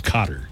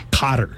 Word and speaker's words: Qatar: The World Cup this year is being held in Qatar, which is a country in the Middle East that used to be pronounced Qatar. And then in the 90s, everybody's like, no, it's Qatar. – Well, I've never heard it Qatar. – Qatar. Cotter. – It's not Cotter. Like, welcome back, Cotter.